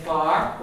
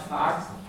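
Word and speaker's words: fear: They say far, when they're talking about a man, and it's not far, it's far. It's a short A, far.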